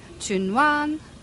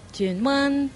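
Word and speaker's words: Tsuen Wan: However, you wouldn't say that because you'd say it right. You would say Chun Wan. – Chun Wan. Chun Wan. – Chun Wan. Right? And then the next one is Sung Wan.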